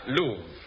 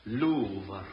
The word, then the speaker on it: Louvre: Stolen from the, how do you pronounce this? – Louvre. L-O-U-V-R-E, pronounced... – Louvre. Louvre. Louvre.